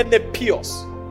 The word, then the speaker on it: nepios: It's a Greek word, N-E-P-I-O-S. But in pronunciation, it's called nepios.